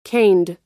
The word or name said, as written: caned